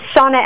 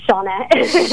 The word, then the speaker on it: Shanna: It's Shanna Ekedal, actually. – Shanna Ekedal. – Spelled like Shanna, but Shanna.